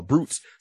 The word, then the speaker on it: Brutes: Now, Brutes spelled B R O O T as in Tom S. But it sounds like Brutes, B-R-U-T-E-S. That's how it's pronounced.